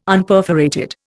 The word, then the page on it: Unperforated: Indian